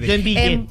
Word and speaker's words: Joinville: no sé cómo se dice correctamente, Joinville, ¿está bien? – Joinville.